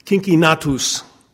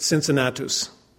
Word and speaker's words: Cincinnatus: His real name, okay, his name in his native Latin language would have been pronounced at the time Cincinnatus, which means curly. We pronounce it Cincinnatus today. And in 1783,